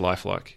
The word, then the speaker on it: LIFEL1K3: Now, the Lifelike, am I pronouncing that right? – It's just Lifelike, yeah.